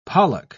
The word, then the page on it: pollock: British English